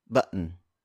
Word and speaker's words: button: For instance, the word button. B-U-T-T-O-N. The two T's are unpronounced. And instead, you hear that really quick pause before letting out that un sound. Again, that's button. Button. Button.